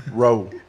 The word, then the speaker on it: Roux: How do you pronounce the baby's name? – Roe, I think. – Roke? – Roe. – Roe? – Why couldn't they just Why couldn't they just spell it ROW? ROUX? I didn't know how to pronounce it, but okay.